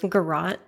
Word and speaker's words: garrote: How do you say that word? – Garrote. – The garrote. I feel like there should be an accent in there.